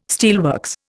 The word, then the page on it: steelworks: Indian